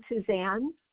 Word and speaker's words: Suzanne: It's my name, Colleen Suzanne at.